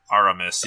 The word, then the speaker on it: Aramis: I feel like Aramaeus. I said that wrong. Artemis. – Aramis. – Yeah. Aramis.